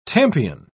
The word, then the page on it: Tompion: British English